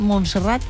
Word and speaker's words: Montserrat: ¿Montserrat? – Sí, Montserrat.